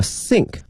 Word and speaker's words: sink: 同学们注意啊，它的发音呢和我们说思考哈，我们想某件这个事情呢有一些接近，但是呢它的这个舌位是不一样的哈。那个思考或想某件事情的话是 think，是这个 think，需要一个咬住上下齿的这么一个发音，而这个是一个 sink，不需要咬的一个轻音哈，sink。